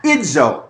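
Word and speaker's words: idzo (ίζω): an ending in the Greek that is idzo, I-D-Z-O, long O.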